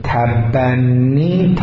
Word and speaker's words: تبنی: تکرار میکنم، تبنی، تلفظ رو دقت بفرمایید، تبنی از ابن میاد، همون بنووت، بنووت و ابوتی که قبلا ازش صحبت داشتیم، بره به این باب به معنای فرزند خواندگی میشه.